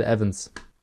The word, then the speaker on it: Evans: Bill Evans. – Zumindest wird er halt im Intro verwendet. – Dennis, es wird Evans ausgesprochen.